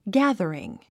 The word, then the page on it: gathering: ɡǽð ə riŋ; ギャザリン